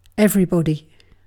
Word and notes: everybody: British English

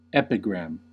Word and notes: epigram: American English

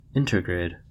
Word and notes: intergrade: American English

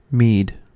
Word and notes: mead: American English

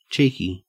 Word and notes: cheeky: Australian English

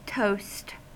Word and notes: toast: American English

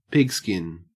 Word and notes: pigskin: Australian English

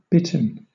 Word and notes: bitten: British English

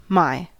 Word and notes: my: American English

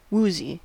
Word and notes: woozy: American English

